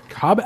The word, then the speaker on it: Cobbett: Richard Cobbett. I don't know if it's Cobbett. Cobbett. How would you pronounce that? Cobbett? – I don't know. How do you pronounce? – It sounds like Hobbit.